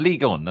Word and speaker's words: Ligue 1: Or Ligue 1, that's how you pronounce it, isn't it? Ligue 1. – Like Formula 1.